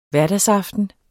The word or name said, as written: hverdagsproblem